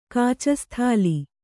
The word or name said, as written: ಕಾಚಸ್ಥಾಲಿ